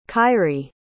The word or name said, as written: kyree